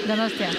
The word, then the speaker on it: Donostia: Donostia. – Donostia.